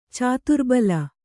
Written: ಚದುರುಬಿಲ್ಲೆ

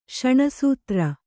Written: ಶಣಸೂತ್ರ

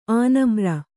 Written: ಆನಮ್ರ

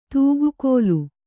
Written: ತುಗ್ಗಿಲ್

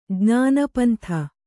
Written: ಜ್ಞಾನಪಂಥಿ

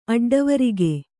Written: ಅಡ್ಡವೊರಗು

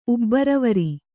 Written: ಉಬ್ಬರವರಿ

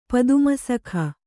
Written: ಪದ್ಮಸಖ

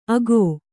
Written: ಅಗೊ

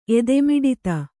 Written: ಎದೆಮಿಡಿತ

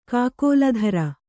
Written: ಕಾಕೋಲಧರ

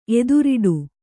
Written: ಎದುರೇಟು

